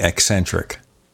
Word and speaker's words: eccentric: With the emphasis on the E, eccentric.